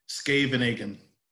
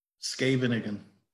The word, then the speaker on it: Scheveningen: Um, and so the poem's titled My Piece in Scheveningen, um, which, like I said, that the throat part is, is hard to pronounce.